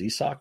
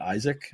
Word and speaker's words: Isak: I was so insistent on making sure I got Paredes' his last name right that I didn't get his first name right, which is Isak, not Isaac.